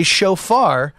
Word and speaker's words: shofar: And you do, like you said, blow a horn, which is called a shofar, to ring in the new year.